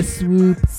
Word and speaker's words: Swoope: I was like, but anyway, we got All the Time, y'all, by Swoope. Have I said that right? – Yeah, you said it right.